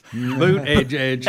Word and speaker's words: Buttigieg: Boot Edge. – That's how you say it, but he said it so funny.